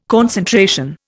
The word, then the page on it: concentration: Indian